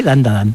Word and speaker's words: Dandadan: Tenemos Dandadan, Dan, creo que lo he dicho bien, Dandadan. – Sí, Dandadan.